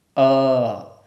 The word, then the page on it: a: sanskrit